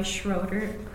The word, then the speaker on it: Schroeder: Officially, I'm L, first initial Joy Schroeder, S-C-H-R-O-E-D-E-R, and I've always been called by my middle name, Joy.